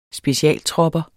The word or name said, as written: specialtropper